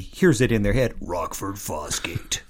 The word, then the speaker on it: Rockford Fosgate: You have to say it like this. Rockford Fosgate.